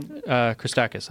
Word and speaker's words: Christakis: Nick Christopoulos. – Yeah, yeah. – How do you say his last name? – Christakis, I think.